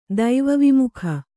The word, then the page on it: ದೈವವಿಮುಖ: Kannada